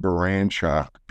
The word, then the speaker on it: Baranchok: My name's John Baranchok, B-A-R, well, you can see it's spelled B-A-R-A-N-C-H-O-K.